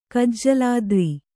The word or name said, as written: ಕಜ್ಜಲಾದ್ರಿ